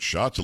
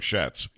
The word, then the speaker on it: Schatz: And it's S C H A T Z. I guess he pronounces it shots. It looks like shats.